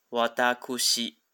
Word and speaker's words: 私: 汉字写一个“ 思”，那其实呢，在常用汉字表里面呢，它的正确读音应该是“ 瓦达库西”，瓦达库西。好，那只是说，一般我们在呃生活里面已经不太会这样讲了，除非在正式的场合，好才会讲“ 瓦达库西”，或者是在日剧里面，你会发觉说，他刻意的去强调他的啊身份，好，他高贵的气质。